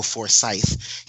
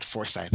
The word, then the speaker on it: Forsyth: His name is pronounced Bill Forsyth. He said that it's not Forsyth, and I will never get it right because I'm so used to saying it the wrong way.